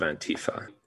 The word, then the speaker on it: Antifa: So this has to do with the kinds of people that form the origin of Antifa. The reason that we pronounce it the way we do is because it has a German origin.